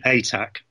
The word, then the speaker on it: Atack: I'm a bit more peaceful than that. I say ATAC. – ATAC. So no. More like a tack, like a thumbtack of a thing.